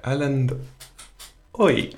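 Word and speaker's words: Erlend Øye: Yeah, so up next we have Like Gold by Erlend Oi.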